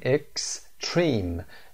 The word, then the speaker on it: Extreme: Extreme استرس روی Stream هست، سیلاب دوم استرس میگیره، تلفظشون هم فرقی نداره.